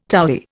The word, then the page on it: towy: Indian